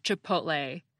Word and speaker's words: chipotle: Let me say unequivocally that the word is pronounced chipotle. That's lay as in rhymes with play, clay, tray, and so forth.